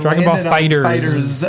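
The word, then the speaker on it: Dragon Ball FighterZ: And that is the correct kind of pronunciation, right? Like we've Dragon Ball Fighters. – Okay, yeah, cool. Got it. – It's the fighter. Putting that to bed. The fighter and the Z are one word.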